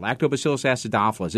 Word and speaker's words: Lactobacillus acidophilus: Lactobacillus acidophilus.